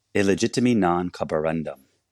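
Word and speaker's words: Illegitimi non carborundum: Illegitimiti non corrobora. Okay, I'm going to say it correctly, okay? Say it for me. – Illegitimi non corroborandum.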